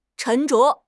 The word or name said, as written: 沉着